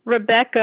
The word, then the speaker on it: Rebecca: Rebecca, R-E-B-E-C-C-A. – And the last name? – Dinkin, D like David, E-N-K-I-N. – D-N-K-I-N? – D-E-N-K-I-N.